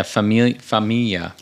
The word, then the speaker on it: Familia: Yeah, Familia.